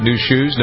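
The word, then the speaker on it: Nu Shooz: New Schnooze. Is that how you pronounce it? New Shoes? No, New Cheeks. – Shoes. Yeah.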